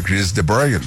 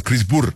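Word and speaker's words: Chris de Burgh: Y entonces le preguntamos eso, ¿cómo se pronunciaba su apellido? Entonces él dijo, bueno, se pronuncia Chris DeBoer, lo pronunció de una forma. Dijo, pero es que nadie lo puede pronunciar bien. – Ajá. – Nos contó que en Rusia le decían Chris Burr.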